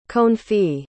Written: confit

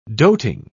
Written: doting

